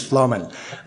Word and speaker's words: Flamen: I like William because he pronounces my name correctly, which is Flamen. And it looks like Flamen, but it's actually pronounced a little bit more Germanic.